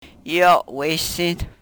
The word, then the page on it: yo we-sin: Yurok